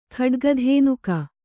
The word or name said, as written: ಖಡ್ಗಿಧೇನುಕ